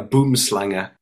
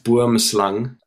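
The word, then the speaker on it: boomslang: he says he's got a boom slanger, which makes South Africans laugh no end, because South African snake, and it's pronounced boom slung.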